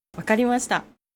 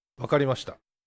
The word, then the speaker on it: わかりました: わかりました。 – わかりました。